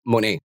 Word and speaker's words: Moné: It's not Moné, kasi it doesn't rhyme with Janelle Moné. Apparently, it's Moné. So, Moné. Okay. – Yes, because of that right. French E thingy. I don't know what it's called, but it's Moné, not Moné.